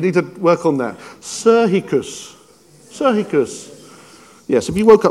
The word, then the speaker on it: psychikos: But the word soulishness here, or that word is psychikos, psychikos. I got the pronunciation written down, psychikos.